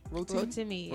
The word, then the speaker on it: Rotimi: Yo, how you say it? Is Rotimi? – Rotimi, yes.